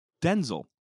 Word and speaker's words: Denzel: We all know Denzel, saying how his name is pronounced Denzel. Denzel Washington.